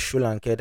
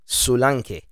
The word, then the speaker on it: Solanke: In Nigeria we pronounce it as Solanke, that is his actual native name, but let's just use what the British guys say, Solanke.